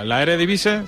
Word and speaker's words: Eredivisie: Que te salga bonita la Champions y la Eredivisie, ¿se dice así? – Sí.